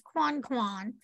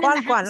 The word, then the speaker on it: Coincoin: Marie Therese Quanquan was born. – Quanquan, I'll say it correctly. Quanquan.